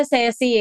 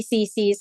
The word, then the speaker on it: CC: I just wanna say, karamihan sabi nila Ceci eh, but good thing it's actually CC.